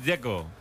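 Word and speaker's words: Džeko: Bruno dice que es Dzeko. – Como tirando a J, Dzeko o algo así.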